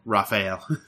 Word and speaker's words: Rafael: Probably Rafael. – Rafael. – Yeah. – Just like the Ninja Turtle.